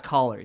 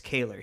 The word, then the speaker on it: Kaler: It's not Kaler. It's Kaler. He wrote me. He's like, hey, it's actually Kaler.